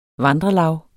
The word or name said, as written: vandrelav